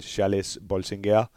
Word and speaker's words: Charles Bolsinger: Øh, jeg har hørt, at det skulle udtales Charles Bolsinger, øh, deres unge franske målmand, som, som virkelig er på vej frem i Montpellier.